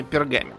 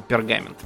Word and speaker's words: пергамен: Но на самом деле, даже когда перешли уже на всякие папирусы, э, на пергамен, кстати, правильно говорить именно пергамен, а не пергамент почему-то, не знаю почему.